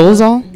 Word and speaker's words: Dolezal: How do you say her name? Dolezal? – Yeah. – That's, oh, okay.